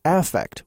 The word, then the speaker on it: affect: And in that field, it's pronounced affect with the emphasis clearly on the first syllable.